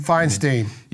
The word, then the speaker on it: Feinstein: Feinstein? – Feinstein.